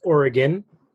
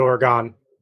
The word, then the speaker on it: Oregon: It's actually pronounced Oregon. If you are from Oregon, they pronounce it as Oregon, not Oregon.